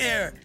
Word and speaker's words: Aire: She told fans on IG his name is A-I-R-E, pronounced as Air.